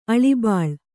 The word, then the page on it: ಅಳಿಬಾಳು: Kannada